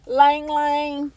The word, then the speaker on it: ylang ylang: Then we have lang lang, which is spelled y-l-a-n-g. Y-l-a-n-g.